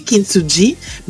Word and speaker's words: Kintsugi: Alors, ça se lit Kintsugi, mais ça se prononce Kintsugi.